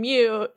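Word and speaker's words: Mu: Oh, I was just going to reiterate, the Mew one was actually that, like, there's some Greek letter that's M-U, that's pronounced Mew, that stands for, like, a collection of everything.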